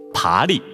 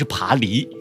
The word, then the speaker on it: 爬犁: 那葛京呢，就是一些细节上需要再注意一下，比如读音啊，爬犁不是爬犁啊，还有一个高空俯瞰东浦。